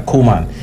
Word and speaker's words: Koeman: I don't know how to pronounce his Koeman. – Name. Yeah, Koeman.